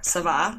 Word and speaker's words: Ça Va: So what I always tell people, if there's like nothing else that they take away besides how to say the name of the bar, Savah, I'll just say it again so every listener can know.